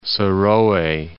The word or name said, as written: Serowe